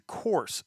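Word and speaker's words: Course (Курс): The name of the release from that band is, it translates to course, C-O-U-R-S-E. And course is, I guess, how you pronounce it. – Course. – Course. And it's, you know, the letters in Russian, to me, being an English speaker, looks like K-Y-P-C, but it's pronounced course. K-U-R-S, essentially, is what Google Translate is telling me.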